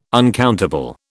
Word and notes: uncountable: British English

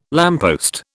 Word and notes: lamppost: British English

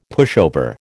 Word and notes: pushover: American English

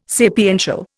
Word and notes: sapiential: Indian

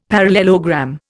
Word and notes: parallelogram: Indian

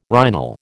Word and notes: rhinal: American English

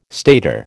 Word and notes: stator: American English

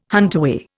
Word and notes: huntaway: Indian

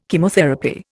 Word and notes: Chemotherapy: Indian

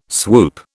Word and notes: swoop: British English

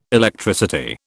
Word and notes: Electricity: British English

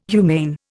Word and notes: humane: Indian

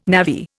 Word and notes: navvy: Indian

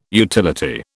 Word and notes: utility: British English

